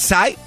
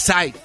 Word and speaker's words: Psy: A lo mejor sí chocó, pero el, gracias a un cuate que se llamaba, ah, se hace llamar Sai, eh, se escribe P-S-Y Sai.